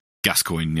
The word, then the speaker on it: Gascoigne: It goes from the very beginning all the way to the, I don't know how to pronounce his name, Father Gascoigne. Is that how you say his name? – Gascoigne, yeah.